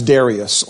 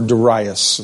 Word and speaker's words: Darius: That was Darius, or Darius, as some pronounce his name.